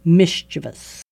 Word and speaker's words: mischievous: It's not four syllables, it's three. Mischievous.